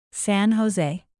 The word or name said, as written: san jose